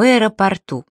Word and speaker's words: аэропорту: Обратите особое внимание на слово «аэропорт». Ударение в нем везде неподвижно. Аэропорта. Аэропортов в аэропортах. Но есть одно исключение. В аэропорту.